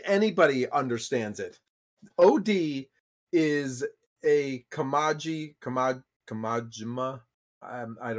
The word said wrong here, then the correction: Kojima: Kojima horror game.